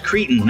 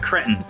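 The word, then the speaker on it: cretin: It's pronounced Cretan, not Cretton.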